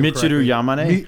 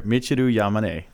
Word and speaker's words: Michiru Yamane: Michiru Yamane. – Michiru, can you do that again? – Sure, Michiru Yamane.